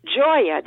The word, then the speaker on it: Gioia: It's Gioia, the Italian word for joy. It's spelled G-I-O-I-A, because we live on Joy Road. And I didn't want to call it the simple English Joy Vineyard, so in deference to my Italian mother, it's Gioia Vineyard.